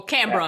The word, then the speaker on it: Canberra: So, or Canberra. Yeah. That's how you pronounce it.